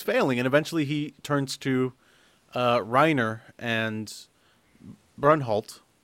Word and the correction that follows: Bertholdt: which I'm sure I'm pronouncing the name wrong. – I think it's Bertholdt. – Reinholt. – Or Bertholdt. – Bertholdt, or something like that. – Bertholdt? – It's Reiner and Bertholdt.